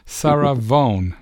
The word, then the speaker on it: Sarah Vaughan: And thanks to you, I learned how to pronounce the name of the next artist, Sarah Vaughan.